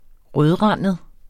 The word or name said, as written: rødrandet